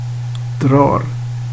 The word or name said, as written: drar